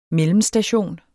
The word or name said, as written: mellemstation